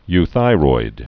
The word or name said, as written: euthyroid